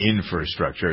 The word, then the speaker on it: infrastructure: It's not infrastructure. It's infra, F-R-A, like library and February.